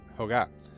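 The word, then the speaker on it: Ohga: Pero entonces el entonces presidente de Sony, eh, Norio Hokka, ah, eso tiene la típica palabra... – Si lo, pronuncia, en andaluz sería Hoga. – Hoga, ya está.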